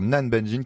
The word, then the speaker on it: Nanbanjin: Elle s'appelle Nabanjin, donc chez Air Libre. – Alors, Nan Banjin qui s'écrit Nanbanjin. Voilà pour la précision.